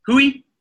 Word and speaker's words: hui: Hui is spelled H-U-I, hui, and it's pronounced hui as in who are you, who am I, hui. Okay, hui. W-H-O is how you would spell who in English. That's how you say it in Māori, hui. And hui means a meeting.